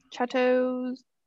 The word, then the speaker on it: Chatot: Chatot.